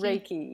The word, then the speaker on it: Reiki: It's Reiki, Reiki, yeah.